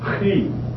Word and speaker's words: chi: Spelled C-H-I. And it's very similar to the way the Germans pronounce the word Bach. They pronounce it Bach, J.S. Bach. And they have a lot of the sound. And so that's how this is pronounced.